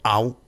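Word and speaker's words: Ow: Vamos a escuchar primero Au, así se escribe O-W y con signo de admiración, Au, ese es el nombre de la composición.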